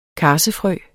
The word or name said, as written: karsefrø